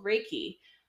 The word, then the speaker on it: Reiki: And today we are going to talk about something called Reiki. I am pretty sure that that's how you pronounce it. – Yep, This is from Japan, and it's basically an energetic healing process.